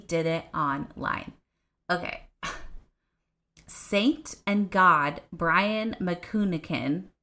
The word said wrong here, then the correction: Manookian: I'm going to spell that one because I did not say it right. M-A-N-O-O-K-I-A-N. Manukian. Like, that was not that hard. I just had to spell it first.